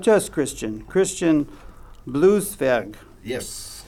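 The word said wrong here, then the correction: Blueszwerg: Christian oh, blue It's Blues Zwerg, like dwarf, uh, blues dwarf, but only in size, not in.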